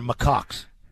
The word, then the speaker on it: macaques: Well, the ones in Thailand are macaques. They're Is that how you say that? – It is. – Is that the correct pronunciation? – Yes.